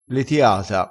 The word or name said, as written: letiata